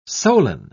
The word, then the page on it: solan: British English